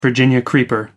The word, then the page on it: virginia creeper: American English